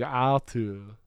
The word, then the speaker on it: Otto: It was basically Arthur, but he pronounced it like Otto. – No, it's Otto. – Otto. – Yeah, because they don't pronounce their R's in Denmark. So it's all very like Arthur would be Otto.